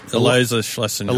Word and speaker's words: Schlesinger: Eliza Schlesinger.